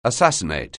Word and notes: assassinate: anglais

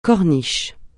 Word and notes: corniche: francais

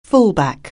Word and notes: fullback: anglais

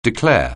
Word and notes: declare: anglais

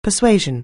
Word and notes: persuasion: anglais

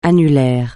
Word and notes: annulaire: francais

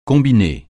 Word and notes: combiner: francais

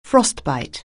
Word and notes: frostbite: anglais

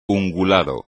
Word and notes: ungulado: espagnol

